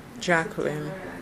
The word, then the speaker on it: Jacqueline: K-A-D-A-J-A-H. – L-A-I-D-L-E-Y. Lately. – Mm-hmm. And then your name? – Jacqueline. J-A-C-Q-U-E-L-I-N-E. Gale. G-A-Y-L-E.